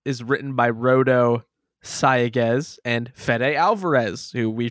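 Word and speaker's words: Sayagues: In any case, this movie was directed by Rodo Sagaez. – Rodo the Barge Sagaez. – I'm sorry, I said his name wrong. Sagaez. Put the syllable in the wrong spot.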